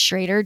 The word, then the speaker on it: Schroeder: Well, then having gotten to know Caleb Schrader, which is spelled Schroeder, but it's Schrader, just right.